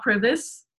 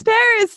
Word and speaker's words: PVRIS: Yeah, it's like when I mentioned Paris, which is that band that's spelled P-V-R-I-S. – It's not Privis? – No, it's Paris. See, there you go. It's like churches too. Churches, C-H or C-V-R.